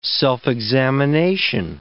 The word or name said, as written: self-examination